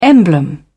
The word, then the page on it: emblem: British English